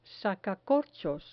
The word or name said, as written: sacacorchos